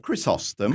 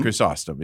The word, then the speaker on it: Chrysostom: Chrysostom. – Chrysostom is how we're going to do that.